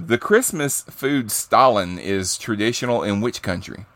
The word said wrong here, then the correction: Stollen: You didn't say it mean enough. – Stalin. It's Stalin. Spell it. How's it spelled? – Stollen. – Stolen. – Oh, okay. – Stolen. I would have known it if you had pronounced it right.